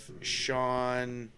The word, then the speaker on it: Sian: It's based on a true story, an actual killing of Sean. It's spelled S-I-A-N, but the way they pronounce it in the show is Sean.